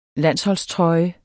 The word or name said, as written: landsholdstrøje